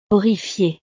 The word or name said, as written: horrifiée